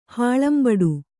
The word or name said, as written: ಹಾಳಂಬಡು